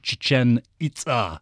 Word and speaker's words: Chichen Itza: No, it's Maya. And so in the Maya language, it's Chichen Itza.